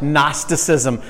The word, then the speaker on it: Gnosticism: The G is silent. Gnosticism. But I like it that way. It's kind of like the word gnu.